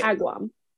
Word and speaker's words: Agawam: Agawam. – I think it's Agawam. I don't think you pronounced the middle A.